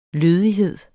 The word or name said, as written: lødighed